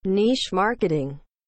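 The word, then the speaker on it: niche marketing: تلفظ درستش در اصل اینه نیش مارکتینگ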